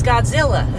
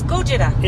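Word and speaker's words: Gojira (ゴジラ): But because of the way Japanese pronunciation is, and because Americans are ignorant, we think it's Godzilla. – Yeah. – But it's Gojira.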